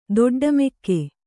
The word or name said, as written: ದೊಡ್ಡಮೆಕ್ಕೆ